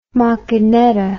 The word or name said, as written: macchinetta